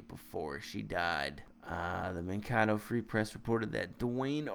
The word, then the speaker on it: Mankato: Uh, it's pronounced Mankato.